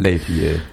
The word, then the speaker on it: Les Vieux: That was Jacques Brel, of course, and Les Vieux. Am I pronouncing it right? His song about growing... – Les Vieux, yeah.